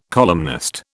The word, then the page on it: columnist: British English